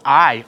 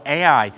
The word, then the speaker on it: Ai: But then next up for them after Jericho is a small city called Ai, or Ai, some pronounce it.